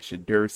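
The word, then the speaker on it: Shedeur: Shadur Sanders.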